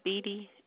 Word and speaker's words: Beattie: My last name is Beattie, and I'm 60409.